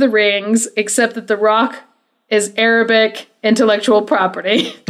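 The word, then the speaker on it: roc: Pretty soon, down, down, down from the mountain comes a rock, which I mispronounced the last episode. I said roke, but it is rock and is a giant eagle-like creature.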